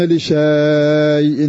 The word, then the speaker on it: لشيء: ولا تقولن لشيء، نحن نحذفها، نحن نحذف في القراءة لكنها مرسومة بالألف، ولا تقولن لشيء مكتوبة في المصحف هكذا بالألف ألف، ولا تقولن لشيء إني فاعل ذلك غدا تجدها في المصحف عندك، ولا تقولن لشيء. ومع هذا فإنا نحذف هذه الألف لا ننطقها،